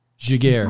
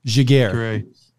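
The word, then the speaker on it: Giguère: Giguere. Giguere is how you pronounce that. Yeah. – That's not how you say that.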